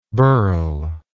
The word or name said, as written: burler